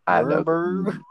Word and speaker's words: Nuremberg: Nuremberg.